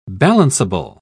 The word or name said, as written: balanceable